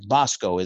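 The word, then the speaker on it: Bosco (βόσκω): Bosco is like the Bosco stick, right? It's not the bread, but it is pronounced that way, to feed, right?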